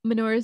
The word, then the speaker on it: Minoru: Minoru Suzuki.